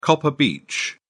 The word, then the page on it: copper beech: British English